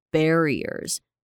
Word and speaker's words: barriers: sociological barriers，记得这边嘴巴张开一点，它是 barriers。有一些人会念得更开一点，像有些人会直接念 barriers，有些人会念 barriers，啊，听起来可能比较像是 e 的感觉，可是其实都是正常的，因为不同地区的人会有不同的发音啊。